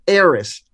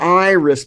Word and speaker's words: Eris: That's Iris. This is Eris. E-R-I-S. – Okay. – Not Iris, but Eris.